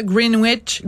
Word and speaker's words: Greenwich: C'est le Daily Mail qui nous explique qu'il y a une université là-bas, Greenwich, on prononce pas le W, je pense, qui a ajouté des trauma avertissements.